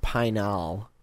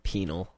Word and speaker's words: Pinal: I think it's pronounced Pinal, not penal.